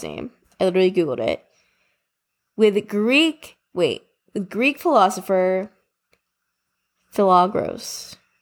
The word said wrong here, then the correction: Pythagoras: Philagoras? Wait, I literally, where's my phone? Pythagoras? I don't even know. H-A-G-O-R-A-S. – Pythagoras.